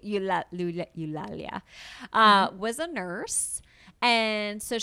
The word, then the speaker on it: Eulalia: Okay, E-U-L-A-L-I-A. Eulalia.